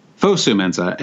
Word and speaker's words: Fosu-Mensah: Did I say his name right? – Fosu-Mensah.